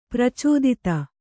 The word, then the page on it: ಪ್ರಚೋದಿತ: pracōdita